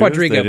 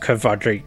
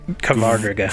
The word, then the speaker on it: Qvadriga: Quadriga, but I'm pretty sure it's pronounced Kvadriga. – Kvadriga.